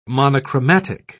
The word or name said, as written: monochromatic